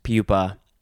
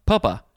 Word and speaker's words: pupa: Noun from 1601, 1A, a pupa of a butterfly, broadly an insect pupa. I want to say the word looks like it's spelled pronounced pupa, but that would have two P's. It's pupa.